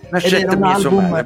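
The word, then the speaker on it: Nascette mmiez'o mare: Nel senso che noi stiamo a fare questa sigla di coda con un album che ti riguarda, perché sto parlando di Nascette Miezzo Mare, spero di averlo detto bene. – Nascette Miezzo Mare, bravo.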